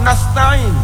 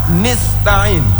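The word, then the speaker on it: nasta'in (نستعين): Dan kasrah nun nasta'in, orang tersebut membacanya nista'in atau kasrah ta-nya nasta'in nasti'in.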